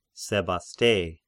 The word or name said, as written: Sebaste